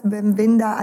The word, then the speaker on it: Bem-vinda: Bem-vinda a nosso podcast. Did I say it right in Portuguese? – Very good. Very good.